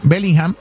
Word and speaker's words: Bellingham: ¿Cómo se llama? Bellingham. Así se pronuncia.